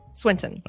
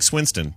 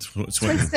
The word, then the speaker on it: Swinton: Tilda Swinton? How do you say it? – Swinton. – All right, there you go. I like Swinston, but Swinton. I think Swinton is accurate.